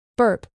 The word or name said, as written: burp